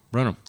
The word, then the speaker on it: Brenham: Was it, how do you pronounce it? Brenham? – Brenham. – Brenham, yeah.